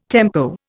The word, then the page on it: tempo: Indian